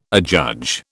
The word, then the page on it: adjudge: British English